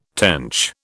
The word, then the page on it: tench: British English